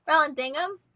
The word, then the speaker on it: Vallandigham: Did I say it wrong? I don't know. Vallandigham? – Vallandigham.